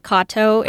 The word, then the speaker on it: Katow: You know, a lot of friends would call me Kapow. – Yeah, but my last name's actually pronounced Kato.